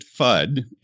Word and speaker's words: FUD: Well, we pronounce it FUD in the film version.